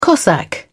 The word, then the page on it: cossack: British English